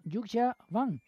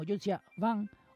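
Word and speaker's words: Yuja Wang: Yuxia, así como suena, Y-U-J-A, y el apellido W-A-N-G. Pero como digo, la pronunciación no se parece a lo que estamos acostumbrados en Occidente. Repito, es algo así como Yuxia Wang o Wang Yuxia, porque ya sabéis que los chinos lo dicen al revés, primero el apellido y después el nombre de pila.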